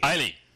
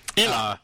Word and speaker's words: Islay: So what I are having right now with our Brie is McClellan's single malt scotch whiskey from, I don't know how you say this in Scotland because I'm sure I'm saying this wrong, but Islay or Islay. – Islay. – That's the one. – Islay.